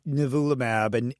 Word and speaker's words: nivolumab: Nivolumab? – Nivolumab.